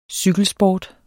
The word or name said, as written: cykelsport